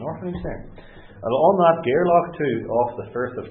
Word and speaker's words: Gairloch: Well, Rue, as Julian will know, I had to look it up, is on the east side of Gairloch. Did I pronounce that properly? – Yes.